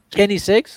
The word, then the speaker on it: Koenigsegg: Koisinex, Koisinex. – Kenisex, eh, ¿no? – Koenigsegg, ok.